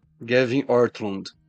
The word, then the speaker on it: Ortlund: E quem escreveu esse livro aqui foi o Gavin Ortland, ou Ortlund. Qual é a pronúncia certa? Gavin Ortlund.